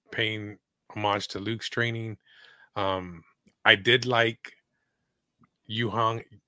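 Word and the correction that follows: Huyang: How do you pronounce it again? – Hu-Yang. – Hu-Yang. – Instead of Hu-Dat, it's Hu-Yang.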